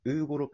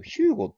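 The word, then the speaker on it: Hugo: ヘッドコーチですけれども、アスフレ、東堂さんのね、対談が発表になっていましたが、えっ、ー、と、ウーロ、ウーロじゃない、ウーゴロペス。はい。なんか、アルファベット読むとさ、えっ、と、ヒューゴって読めるなと思ったんだけど、ウーゴなんだね。ヒューゴじゃないんだね。うん。ウーゴ・ロペスさんの就任が発表になりました。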